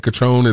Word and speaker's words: Cotrone: I've been saying Cotrone.